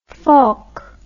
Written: volk

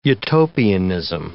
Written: utopianism

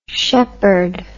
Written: sheppard

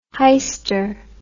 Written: heister

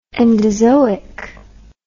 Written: endozoic

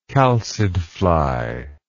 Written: chalcid fly

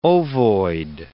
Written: ovoid

